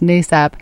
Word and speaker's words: NASAP: So it's pronounced NASAP.